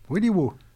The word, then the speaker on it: Wiliwaw: Willy Woo. – Voilà. On finit en musique. – W-I-L-I-W-A-W.